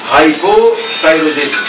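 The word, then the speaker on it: hypothyroidism: انت عارفين انا بنطقها كده ليه؟ عشان انتوا برضه في الشفا هتنطقوها. عشان لما بتقول بسرعه ان هايبو ثايرويديزم ممكن تبان انها هايبر هايبر، انا بقول لك ان هايبو ثايرويديزم لا ان هايبر ثايرويديزم، انما بقول هايبو ثايرويديزم هايبر ثايرويديزم.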